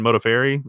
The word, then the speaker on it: Modafferi: Macy Barber and Roxanne Modafferi is how you say it.